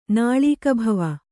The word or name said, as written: ನಾಳೀಕಭವ